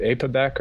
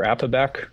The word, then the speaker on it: APOBEC: So the ApoBec enzymes, one can pronounce it ApoBec or ApoBec, kind of like tomato, tomato. It stands for apolipoprotein B mRNA editing catalytic subunit 1.